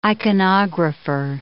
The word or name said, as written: iconographer